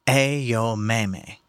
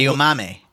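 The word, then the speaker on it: Aomame: and pronounced with the same four syllables, Ayomame. So Ayomame.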